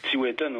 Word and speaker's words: Tiwetan: Tu as nommé une chanson, Tiwetan. Je sais pas si je la prononce bien. Mais... – Tiwetan, oui. Le vent du Nord.